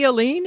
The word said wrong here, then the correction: Allen: Is that how you pronounce her name? – Oh, Stephanie Allen. – Allen, whatever.